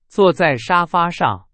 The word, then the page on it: 沙发: Chinese